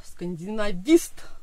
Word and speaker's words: скандинавист: Сегодня с нами Ксения Коваленко, главный редактор издательства «Белая ворона», а переводчик со шведского и норвежского языков, скандинавист, да, так это произносится.